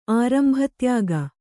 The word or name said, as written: ಆರಂಭತ್ಯಾಗ